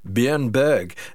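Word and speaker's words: Björn Borg: At one point, somebody actually had the thoughtfulness to say to him, how do you actually pronounce your name? And actually, believe it or not, it's pronounced Bjorn Borg. That's how they pronounce it. We'd be pronouncing it wrong forever.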